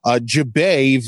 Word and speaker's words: Jubei: He is currently now in language study for Mandarin Chinese, and his family attends Jubei Victory Church. Did I pronounce that right? – Jubei Victory Church. – You got the tones right too. Great.